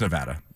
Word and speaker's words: Nevada: It's Nevada. That is the correct Nevada. – That is the correct pronunciation.